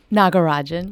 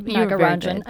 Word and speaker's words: Nagarajan: I think I'm saying that wrong. – Nagarajan. – Nagarajan. I was close, but not quite.